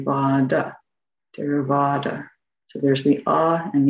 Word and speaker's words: Theravāda: It's not like th, like we don't say Theravada, like the word therapy, although it's a nice link, I guess, but that would be a Theravada. So a T with an aspiration after it, Theravada. And then PH is not pronounced like F in Pali.